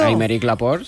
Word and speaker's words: Aymeric Laporte: Aymeric Laporte, sí. – Ay, qué bien pronunciado. – Es francés, claro.